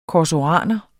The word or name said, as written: korsoraner